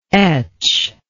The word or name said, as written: etcher